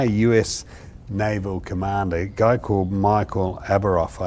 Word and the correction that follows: Abrashoff: I think his name is, I think I pronounced it right, Abrashoff.